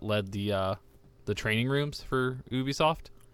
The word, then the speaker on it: Ubisoft: I think I've mentioned it before, but now that I don't work at that retail chain anymore, he was Ubisoft, however you want to pronounce it. No, Ubisoft. – Ubisoft.